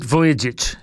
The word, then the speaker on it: Vujicic: Nick Vujicic, yep.